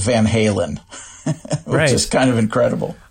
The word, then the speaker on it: Van Halen: There develops this thing where the person being addressed gradually adopts the mispronunciation as a nickname, like which happened to the band Van Halen, whose name is actually Van Halen. And I think that's kind of incredible. Like, their entire career is based on an Americanized mispronunciation of a Dutch surname.